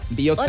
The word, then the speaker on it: Biotza: Con TZ. Ojo, TZ. – Biotza. – Que no tenemos TH, tenemos TS, TZ y TX. La TX se pronuncia como ch. La TZ como. Y la TS como TS, o sea, como una CH normal.